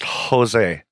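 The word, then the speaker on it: Gose: Other types include Lambic, made with wild yeast, Berliner Weiss, a cloudy sour beer, and a Goza, a sour salty beer. – I believe it's pronounced Jose. – Jose. – It's a soft G.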